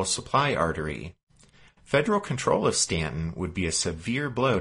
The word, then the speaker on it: Staunton: Stanton, and if you're looking on your map, it's spelled S-T-A-U-N-T-O-N, but pronounced Stanton,